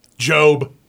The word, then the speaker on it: GOB: G-O-B. – Do you know how to say it? – Job.